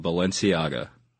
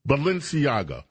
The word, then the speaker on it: Balenciaga: How do you, how do you pronounce that damn fashion house name again? I get a block with it. – Balenciaga. – Balenciaga. – Correct. – Okay, thank you. Balenciaga.